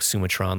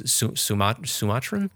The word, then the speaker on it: Sumatran: It's not like Sumatron, like a robot. It's Sumatran? Sumatran bamboo rat can weigh up to 3.5 pounds, 8.8 pounds, or 22 pounds.